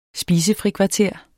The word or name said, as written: SPISEFRIKVARTER